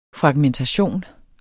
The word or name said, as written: fragmentere